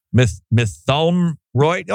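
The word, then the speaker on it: Mytholmroyd: I look, Mithumroyd. – Is that how you say it? – Yeah, I looked it up. – M-Y-T-H-O-L-M. Mithumroyd? Oh, that sounds about right. – Yeah, that's it.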